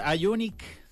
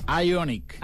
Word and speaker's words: Ioniq: Señor director, Ioniq 5N, así se pronuncia, ¿no? – Así es. – Ioniq. – Ioniq.